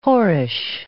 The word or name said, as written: whorish